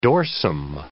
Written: dorsum

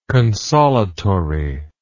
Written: consolatoriness

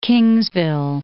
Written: Kingsville

